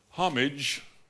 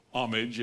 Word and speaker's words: homage: And so uh, the word serve is not a hard word to understand, but for Christians, it simply means to render homage or homage if the H is silent.